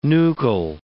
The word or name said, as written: nuchal